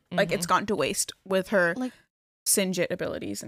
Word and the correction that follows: Signet: And is that what you say? Signet. Signet abilities.